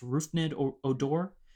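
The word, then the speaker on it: Rougned Odor: Okay, my honorable mentions, Rangers Blue Jays 2016, Jose Bautista, and I believe it's pronounced Rufnid Odor.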